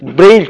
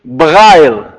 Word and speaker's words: Braille: Braille kelimesi aslında İngiliz telaffuzu ile söylenen bir kelimedir. Kendisi Fransızdır Louis Braille bildiğiniz gibi. Aslında Louis Braille denmesi gerekir ama Fransızlar maalesef Louis Braille'e fazla sahip çıkmadıkları için İngilizler kadar Braille olarak dünya literatüründe telaffuz olarak daha çok kullanılıyor.